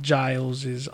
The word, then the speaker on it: Giles: It always looks like Giles' office.